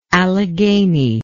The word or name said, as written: alleghanian